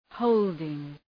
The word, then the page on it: holding: ‘həʋldıŋ